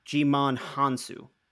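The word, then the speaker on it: Djimon Hounsou: Okay, so according to IMDb, it's pronounced Jimon Hansu.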